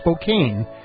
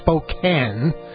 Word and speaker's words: Spokane: Which on the other radio show, I made the mistake. And I admit it was a mistake of pronouncing it Spokane instead of Spokane. But the reason I pronounced it that way is because I was following the rules of English, which is the language I was taught. And it has to do with the silent E and the long vowel that precedes it. So K-A-N-E, which is how that word ends, has a silent E.